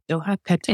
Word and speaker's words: Doha, Qatar: And how do you pronounce it? – Doha, Qatar. In Doha, Qatar.